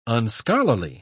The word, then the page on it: unscholarly: British English